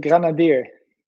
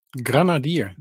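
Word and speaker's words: Grenadier: En eh, wij, wij, vroegen ons af, eh, hoe spreek je het uit? Jullie nieuwe hoofdsponsor. Of jullie nieuwe sponsor. – Ja, als ik het goed zeg, Granadier. – Granadier.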